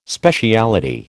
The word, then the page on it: speciality: American English